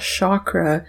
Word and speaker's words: chakra: And the reason I keep saying it like that and not chakra is a couple people reached out to let me know that the correct pronunciation is chakra, but I also just like to say energy center, and I feel like that just reflects me and my Western perspective better.